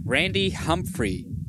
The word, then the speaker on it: Humphrey: Today I have Randy Humphrey.